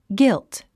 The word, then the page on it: guilt: ɡílt; ギィゥツ